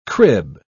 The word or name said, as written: crib